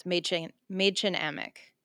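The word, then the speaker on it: Mädchen Amick: I'm probably going to butcher pronunciation, but it's Maidchen Amick. – Oh, that's right.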